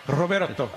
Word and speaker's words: Roberto: Roberto. And roll your R's, too. Roberto.